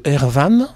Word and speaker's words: Erwan: Une chanson de Erwan, c'est comme ça que ça se prononce, Erwan.